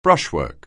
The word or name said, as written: brushwork